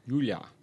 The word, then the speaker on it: Giulia: Giulia. – And that's spelled G-I-U-L-I-A.